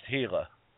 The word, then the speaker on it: Gila: Gila, G-I-L-A, but it's pronounced Gila.